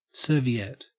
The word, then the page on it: serviette: Australian English